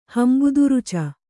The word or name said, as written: ಹಂಬುದುರುಚ